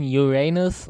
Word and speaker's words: Uranus: Ja, also man muss es Englisch aussprechen, Uranus, und dann macht man daraus halt deinen Arsch.